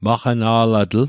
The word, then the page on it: maqana'latl: Mi'gmaq